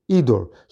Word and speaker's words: idur: But as I'm al tikri hador eli idur, the way to pronounce it is idur.